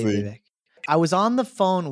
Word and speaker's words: Vivek: But his name is Vivek. That's how you say his name, Vivek.